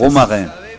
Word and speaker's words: romarin: Romarin, romarin.